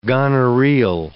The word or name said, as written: gonorrheal